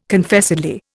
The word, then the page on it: Confessedly: Indian